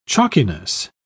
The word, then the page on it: chalkiness: British English